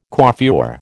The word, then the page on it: coiffure: American English